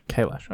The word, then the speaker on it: Kailash: Is that actually how you say it? – Kailash. – Kailash. Okay.